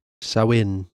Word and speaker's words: Samhain: The pronunciation part is spelled S A H W H E N. – Samhain.